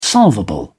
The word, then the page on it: solvable: British English